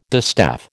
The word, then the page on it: distaff: American English